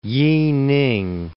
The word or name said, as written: Yining